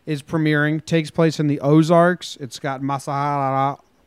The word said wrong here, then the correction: Mahershala: Masahara Ali. I think is his name. – Mahershala Ali. – How do you pronounce it? – Mahershala Ali. – Yep.